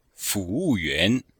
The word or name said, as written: fu2wu4yuan2